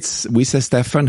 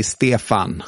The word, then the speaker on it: Stefan: Yeah, we say Stefan here. – Okay. – But in Swedish, we say Stefan.